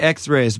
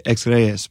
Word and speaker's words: Ex Reyes: Ex Reyes. – I say Ex Reyes, but really like it's Spanish for Ex King. So we say Ex Reyes.